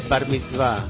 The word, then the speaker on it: Bar Mitzvah: Es como de cumpleaños de Barney Bá, digamos, ¿no? Cumpleaños de 15, Barney Bá, de esa onda, ¿no? ¿Qué pasa? – No, que no se dice así, se dice Bar Mitzvah.